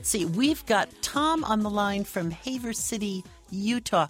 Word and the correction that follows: Heber: Tom, did I pronounce that right? Haver? – Heber. – Heber. Excuse me, sir.